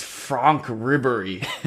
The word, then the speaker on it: Franck Ribery: It's Franck Ribery.